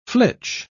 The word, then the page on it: flitch: British English